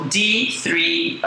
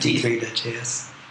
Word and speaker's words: D3.js: So D3J, how do you say that? – D3.js. – .js.